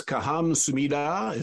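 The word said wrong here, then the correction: Kamsahamnida (감사합니다): Is that? – It's Kamsahamnida. – Kamsahamnida. Okay. I butchered it a little bit.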